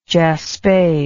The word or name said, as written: jaspe